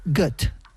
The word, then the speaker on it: Goethe: Y según he visto, se pronunciaría Goethe. – Goethe. – Goethe. Mira, bueno, como Gotha.